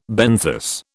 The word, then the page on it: benthos: British English